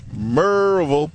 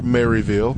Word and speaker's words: Maryville: Mike Bledsoe of Merville. Or Maryville, however you want to pronounce it.